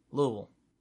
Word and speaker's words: Louisville: You have to say it with as little enunciation as possible. Louisville. – Yeah, you pretty much have to pronounce it like your mouth is full of chewing gum.